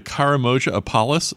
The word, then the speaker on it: Karamoja apalis: But well, that reminds me, we only have minutes left, but I want to talk about the Karamoja apalis. Is that how you pronounce it? – Yeah, in Africa, East Africa.